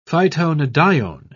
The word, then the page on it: phytonadione: British English